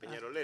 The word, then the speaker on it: Peñalolén: Siguen llegando mensajes ahora Raúl Céspedes desde el barrio de, díganme si lo pronuncio bien, Peñalolen. Ah, Peñalolén. Peñalolén. Peñalolén, ahí va, desde Santiago, dice que los llevamos en el corazón.